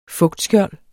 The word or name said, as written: fugtskjold